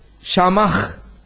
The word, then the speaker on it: Shamach: Nonetheless, Shamach, it's pronounced with a guttural sound.